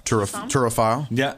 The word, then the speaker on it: turophile: Certified cheese license, professional cheese somm, turophile.